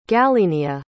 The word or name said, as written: galenia